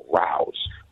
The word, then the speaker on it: Rao's: So we went to dinner last night and suggested that we go to a place called Rouse. – R-A-O-S. Spell that one more time. – R-A-O-S, Rouse.